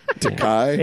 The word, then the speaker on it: Takei: Do I say his name? – Takei? – It's Takei. It's okay, and you too.